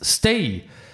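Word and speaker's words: Stage: Mitte Nummer sechs, Jens Stay. – Das ist der, der Starge geschrieben wird, aber wird Stay ausgesprochen. Jens Stay.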